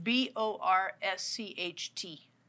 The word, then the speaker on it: borscht: How do you say it? Bort? B O R S C H T. – Borscht.